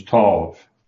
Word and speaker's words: Tov: I have to smile because in Israel they tend to pronounce that instead of with a long O. They do it with an ah kind of O. So it's Tov on that.